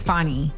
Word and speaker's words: Fani: I've heard it pronounced Fannie too many times now.